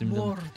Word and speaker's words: Mordor: And I try to pronounce it, you know, like the way it should be pronounced. – Mordor. That little trill of the R.